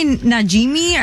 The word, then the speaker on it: Najimy: Kathy Najimy.